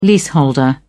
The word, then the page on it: leaseholder: British English